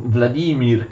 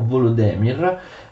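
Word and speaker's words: Volodymyr: Vladimir, che attenzione, gli ucraini scrivono così e eh, pronunciano Volodemir, e invece era stato dato il territorio di Novgorod, quindi tre figli, tre territori appartenenti a, uh, a Kiev.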